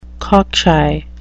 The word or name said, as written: cockshy